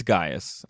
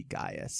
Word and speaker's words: Caius: And then and with them is this guy named, it's spelled Caius, but usually I'm like, it's Gaius, I'm sure. – Yeah, going back to Roman times, it would just be Gaius. So yeah, we're gonna say Gaius.